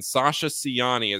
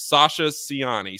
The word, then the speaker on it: Sasa Ciani: A Slovenian big man, Sasa Ciani, is how I was told, at least, that we were pronouncing this.